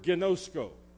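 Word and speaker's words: Ginosko (γινώσκω): I want you to write the word ginosko as the number 3 and spell it just like I got. G-I-N-O-S-K-O.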